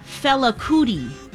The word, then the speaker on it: Fela Kuti: The man's name is Fela Kuti.